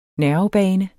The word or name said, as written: nervebane